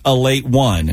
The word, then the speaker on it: Ale8-1: And if you don't know what an Ale8-1 is, a lot of people think it's Ale8, the drink, but it's actually pronounced Ale8-1. If you look at, there's the little one there at the end.